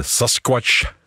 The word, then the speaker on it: Sasquatch: Oorspronkelijk heette die Sasquatch. Ja. Let vooral op de tweede lettergreep.